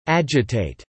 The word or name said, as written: agitate